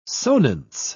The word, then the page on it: sonance: British English